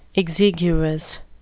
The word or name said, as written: exiguous